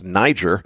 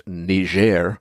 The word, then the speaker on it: Niger: Now, the backstory here is Niger, and if you're not sure what I'm talking about, the West African country that's uranium-rich and looks to English speakers like it ought to be pronounced Niger. Well, that's a French-speaking place, so it's pronounced Niger. And their situation, they had a coup, essentially.